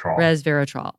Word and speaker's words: Resveratrol: Resveratrol.